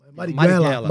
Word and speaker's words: Marighella: Magela não, Marighella. Marighella. Marighella.